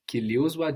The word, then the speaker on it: kiliuzwa: Kitabu hiki kili uozwa juzi. – Kitabu hiki kiliuzwa juzi.